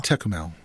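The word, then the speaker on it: Tékumel: Who is the organizer of Madison Traditional Gaming and the president of the Tecumel. Am I saying that correctly? Tecumel? – Tecumel. – Yes. Tecumel.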